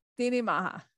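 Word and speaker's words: Daoine Maithe: Priestess of the Deoin Matha. Is that correct? – Dini Maha. It's Irish. And I know Irish is very different for English speakers to look at, because it has different grammar and pronunciation rules, but it's Dini, which means people, and then Maha, which means good.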